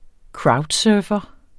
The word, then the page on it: crowdsurfer: ˈkɹɑwdˌsœːfʌ